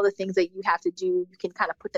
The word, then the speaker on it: Teux Deux: Another personal habit that's helped me significantly, to-do lists. So I actually use an app. It's called To-Do, but it's spelled T-E-U-X. D-E-U-X.